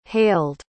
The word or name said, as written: hailed